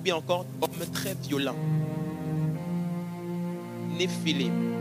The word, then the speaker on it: Néphilim: Le mot géant ici, c'est un mot hébreu qu'on prononce Néphilim. Le singulier, le mot singulier, c'est Néphel. Au pluriel, c'est Néphilim.